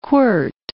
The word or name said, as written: quirt